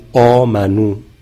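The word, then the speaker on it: آمنوا: مثلا آمنو، خب الف آخرش خونده نمیشه، یا فرض کنید اولائک، واو بین همزه و لام خونده نمیشه.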